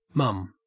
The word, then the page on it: mum: Australian English